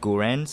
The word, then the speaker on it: Gurren: in Gurren Lagann, I can't really pronounce that word, but it's pronounced Gurren. So if you search it, G-U-R-R-E-N on Netflix, you should be able to find it.